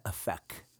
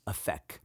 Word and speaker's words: Aphek: I think an episode or two ago, I was just in Israel, and the Jewish people, they pronounce that Aphek, Aphek.